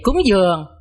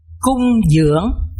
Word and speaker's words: cung dưỡng: Vấn đề cúng dường đọc cho đúng thì nên đọc là cung dưỡng,